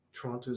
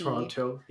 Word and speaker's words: Toronto: Sorry, I'm laughing because you can always tell who's not from here because they pronounce the T. – Toronto, yeah.